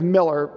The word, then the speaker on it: Mueller: So one of my favorite books on Sabbath, the guy's name is Miller. It's spelled Mueller.